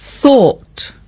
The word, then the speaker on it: thought: Pero luego el pretérito se dice thought, y se escribe T-H-O-U-G-H-T, thought.